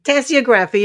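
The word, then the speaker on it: tasseography: Tassiography? – Tassiography, yes.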